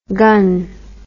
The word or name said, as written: gunn